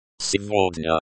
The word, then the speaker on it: Сегодня: Сегодня. Сегодня, the there is pronounced like the D in day. The ya is pronounced like the ya in yard.